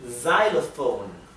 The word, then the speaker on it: xylophone: X for xylophone. Xylophone, ¿sí? La X en inglés a veces se pronuncia z, como aquí, y a veces se pronuncia kz, ¿sí?